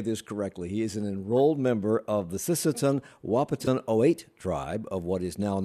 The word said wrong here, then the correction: Oyate: Did I even get that close, Curry? – Very close. Oyate. – Say it again. Say the whole thing. – Sisseton Wahpeton Oyate.